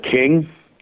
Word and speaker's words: Kyng: It's like, I think it came out like 2-3 years ago, called King. It's spelled KYNG.